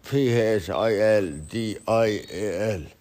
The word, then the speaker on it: Phil Dial: It's uh, eh, P H I L D I A L Phil Dial like, like fucking dial the phone like his Davis and he told me he educated me many times on how to make a podcast.